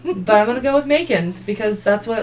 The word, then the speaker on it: Macon: Is that how you say it? Macon? M-A- it looks know. like bacon with an M. Okay. Macon.